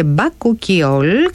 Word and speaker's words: Bakuchiol: Προφέρεται Bakuchiol και τώρα καταλαβαίνω γιατί.